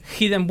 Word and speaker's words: Hidden: Es, se llama la Hayden Wiki. – Ah, Una Wikipedia. – Sí, Wiki, Hayden no, Hidden. Hidden, se pronuncia Hidden.